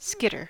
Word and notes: skitter: American English

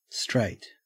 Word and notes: straight: Australian English

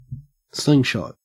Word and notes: slingshot: Australian English

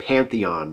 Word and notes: pantheon: American English

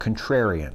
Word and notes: contrarian: American English

